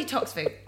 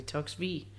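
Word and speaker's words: Toksvig: Sandy Toxvig. Am I saying that correctly? – Yeah, very good. Unless you're Danish, in which case you would say Sandy Toxvig. Okay. – And we don't say the G, but the British find that almost impossible.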